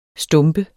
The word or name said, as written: stumpet